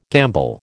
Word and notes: gamble: American English

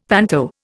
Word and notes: panto: Indian

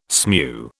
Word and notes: smew: British English

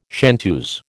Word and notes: chanteuse: American English

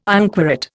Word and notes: inquorate: Indian

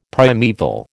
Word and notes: primeval: American English

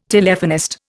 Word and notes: Telephonist: Indian

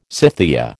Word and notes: Scythia: American English

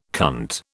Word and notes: cunt: British English

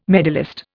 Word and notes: medallist: Indian

